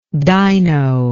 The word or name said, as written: dyno